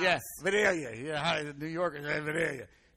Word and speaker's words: Vidalia: And by Real Sweet Onions by Schumann Produce, they are the leaders in Vidalia, and that's how you say it, Vidalia. Can we all say it together? – Vidalia. – Vidalia.